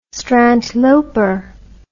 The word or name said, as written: strandloper